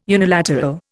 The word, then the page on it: unilateral: Indian